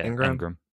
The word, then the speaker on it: Engram: Evan Engram. Am I saying that right? – Yeah, Engram.